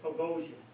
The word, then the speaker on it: Boghossian: Peter Boghossian, and I don't know if that's how he pronounces it. What is it? – Boghossian.